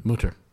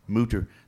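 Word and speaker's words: Mütter: How do you pronounce it again? – Mutter. – Mutter, the Mutter Museum.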